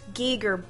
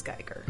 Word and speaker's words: Giger: Okay, so it's correctly pronounced Giger, but the American pronunciation, which they call a mispronunciation, is Geiger.